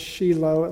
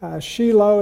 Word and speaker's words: Shiloh: The correct pronunciation is Shiloh, at least that's the way the Jews say it. Shiloh, it was there for many years, and then finally moved to Gibeon, which is about 5 miles northwest of Jerusalem.